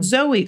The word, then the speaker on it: Zoe: However, she pronounces it Zoe. Oh, not Zoe. Thank you, Rep Zoe Lofgren.